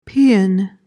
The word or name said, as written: paean